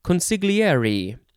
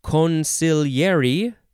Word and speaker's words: consigliere: It can be pronounced a variety of ways. It is the word consigliere. So you can say consigliere. Consigliere, consigliere, consigliere, consigliere, consigliere. Yeah, there's so many ways.